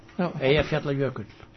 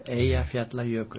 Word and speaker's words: Eyjafjallajökull: Eyjafjallajökull. – Let me hear that. – Eyjafjallajökull. – Eyjafjallajökull. – Eyja means island. Fjalla is mountain. And Jökull is glacier. Eyjafjallajökull.